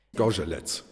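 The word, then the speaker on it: Zgorzelec: Skorzelec.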